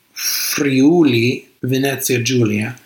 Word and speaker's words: Friuli Venezia Giulia: So it's pronounced Friuli Venezia Giulia, near Trieste and sort of borders Croatia and Slovenia in that whole area.